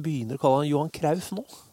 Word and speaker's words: Cruyff: Men uh, den, st uh, store greia Jeg vet ikke om det er elefanten i rommet eller hva det er, men Johan Cruyff er uttale av navnet hans. Ja. det er jo Johan Det, det er jo Krauf.